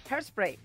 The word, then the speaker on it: Hairspray: ¿Lo he dicho bien? – No. – ¿Cómo es? ¿Cómo es? – Hairspray. – Hairspray.